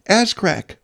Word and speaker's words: Azcraq: It's spelled A-Z-C-R-A-Q. Ascrack.